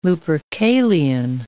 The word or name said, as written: Lupercalian